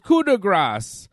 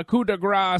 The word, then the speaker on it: coup de grâce: It's grâce, spelled G-R-A-C-E. Mardi Gras is spelled G-R-A-S. Gras is fat. Grace is grace. It's two different words. So when Pat McAfee says Mardi Gras, coup de gras, same thing, I'm like, no, it isn't, Pat McAfee, you stupid fuck.